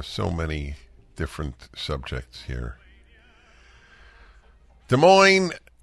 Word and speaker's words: Des Moines: And here in Washington, we say a Z sound at the end. Des Moines.